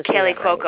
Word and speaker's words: Kaley Cuoco: I saw some of the people coming: Roseanne Barr, Bradley Whitford, and Kaley Cuoco. Is that how that's pronounced? I never. – Kaley Cuoco.